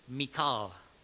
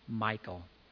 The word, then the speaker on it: Michal: Well, one of them pronounced it Michal, which probably is pretty close to the Hebrew, but I don't know. And the other said the common pronunciation is Michal.